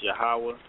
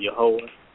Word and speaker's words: Yahweh: it would be YHWH, and it will be pronounced Yahweh. Some people may pronounce it Yahweh. Some people may pronounce it Yehovah.